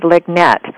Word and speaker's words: LIGNET: You're with managing editor of Lignet. Did I pronounce that right? – Yes.